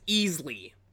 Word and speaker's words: Isley: They pronounce it as Easley, as well as two other beings whose significance isn't revealed until later on.